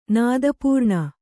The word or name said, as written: ನಾದಪೂರ್ಣ